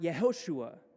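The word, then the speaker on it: Yehoshua (יהושע): And in Hebrew, it's pronounced Yehoshua, which is literally God's name plus the word, the Hebrew word for salvation.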